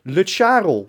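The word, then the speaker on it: Lutsharel: Het is niet Le Charol, het is Le Charol. – Ja, maar het moet rijmen op Karel.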